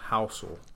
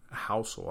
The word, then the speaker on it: Housel: So the first thing that you should do with that $1,400 is buy a book called The Psychology of Money by Morgan Housel.